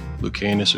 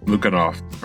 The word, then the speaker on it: Lukianoff: We're looking at the book is The Coddling of the American Mind by, I think, Greg Lukianus or something. And Lukianoff.